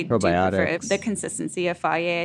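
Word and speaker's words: Fage: I prefer faye.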